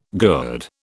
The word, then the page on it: gourd: British English